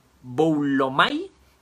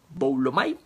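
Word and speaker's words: boulomai (βούλομαι): esta palabrita voluntad proviene del griego boulomai, con B grande y acento en la U, boulomai, y significa desear reflexivamente.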